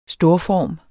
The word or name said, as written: storform